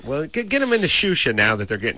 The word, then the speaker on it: Xuxa: All right, Drew, get on the internet and go find Shusha. – How do you spell it? – X-U-X-A. – X-U-X-A?